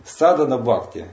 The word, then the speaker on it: садхана-бхакти: Садхана-бхакти или садхана-бхакти пишется. Там хэ, но она не произносится.